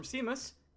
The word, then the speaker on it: Seamus: It's a C, like, you know, the ocean, and then Mus, M-U-S. – S-E-A-M-U-S? – Yeah.